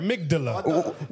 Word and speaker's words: amygdala: Amygdala. Amygdala. – Make sure you use the I didn't know there was a D in there. Amygdala.